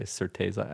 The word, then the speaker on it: Certeza: A lot of people say Certeza, actually. – Yeah, but it's Certeza. – Yeah, for those familiar with Spanish, Portuguese, Latin base in general, Certeza is mostly how they say it, depending.